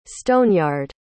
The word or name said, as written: stoneyard